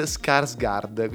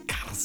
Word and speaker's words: Skarsgård: come si pronunciasse e l'attore che fa It è Bill Skarsgård, quindi Skarsgård.